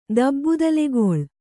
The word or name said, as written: ದಬ್ಬುದಲೆಗೊಳ್